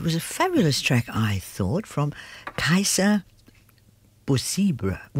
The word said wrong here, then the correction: Bouzriba: No, Busriba. And that's spelled K-A-I-S-S-E-R. Busriba is B-O-U-Z-R-I-B-A.